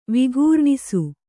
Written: ವಿಘೂರ್ಣಿಸು

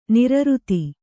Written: ನೈರ್ಋತ

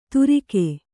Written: ತುರಿಗೆ

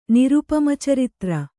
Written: ನಿರುಪಮಚರಿತ್ರ

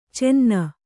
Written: ಚೆನ್ನ

